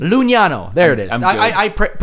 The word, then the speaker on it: Lugnano: Lugnano. There we go. I actually listened to how it would be pronounced. So, because that's where it is, Lugnano.